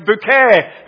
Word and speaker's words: Bucket: Spelt Bucket. B-U-C-K-E-T. But she wanted it pronounced Bouquet because she was posh.